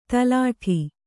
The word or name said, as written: ತೆಲ್ಲಟಿ